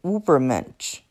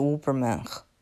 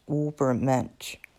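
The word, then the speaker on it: Übermensch: 哦，最后我得纠正一下刚才我的发音，应该是 Ubermensch，不是 Ubermensch。嗯，这个德语最后这个发音应该是 Ubermensch。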